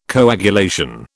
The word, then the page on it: coagulation: British English